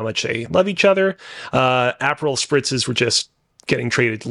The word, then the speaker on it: Aperol: Aperol spritz, which I, by the way, okay, for the record, I mispronounced as an apple spritz because I thought it was apple flavored.